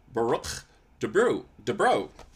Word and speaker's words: Boruch DuBrow: I'm afraid I'm gonna butcher it as it is here, but I have Rabbi Boruch DuBrow.